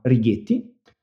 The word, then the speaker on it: Rigetti: Abbiamo delle macchine costruite da un'altra startup che è a Berkeley, in California, che si chiama Righetti. Infatti, per dire in italiano si dovrebbe dire rigetti, perché R-I-G-E-T-T, però negli Stati Uniti la pronunciano con un H.